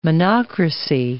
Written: monocracy

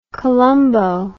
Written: columbo

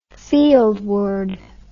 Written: fieldward

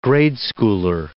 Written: grade-schooler

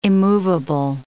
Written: immovable

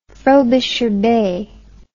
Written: Frobisher Bay

